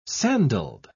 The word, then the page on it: sandalled: British English